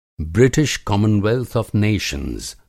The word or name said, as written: ब्रिटिश कमनवेल्थ आफ़ नेशंस